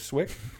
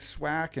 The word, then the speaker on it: Swick: Nobody could say Swick until Mike Goldberg called me Quick, because Quick rhymes with Swick.